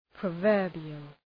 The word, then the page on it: proverbial: prə’vɜ:rbıəl